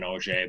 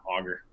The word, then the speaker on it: Auger: Auger is actually the French pronunciation. So growing up, I heard a lot of people, especially refs from, you know, Montreal area and stuff, saying Auger, but yeah, Auger. Auger's right.